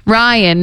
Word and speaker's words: Ryan: Ryan. – Grian? – Ryan, no G. – Oh, Ryan. – Because well, then there's Grian with the silent G.